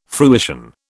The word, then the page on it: fruition: British English